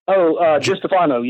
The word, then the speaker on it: DiStefano: So when you, when you work with guys like, Destfanano, did I say that right? Destfanano? – DiStefano, yes. – DiStefano, okay.